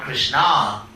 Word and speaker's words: Krishna: She has many different names, and also she's called Krishna, with a long A at the end, feminine, because she was so devoted to Krishna.